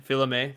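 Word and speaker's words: Fils-Aimé: Well, maybe GameStop has been saved because Reggie, last name I can't pronounce, from Nintendo, Philame, thank you.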